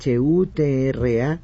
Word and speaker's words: ghutra: Pocos entendieron que la mascota oficial llamada Laeb, un turbante o gutra animado, no sé si así se pronuncia, es Ghutra, gutra animado.